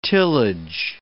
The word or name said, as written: tillage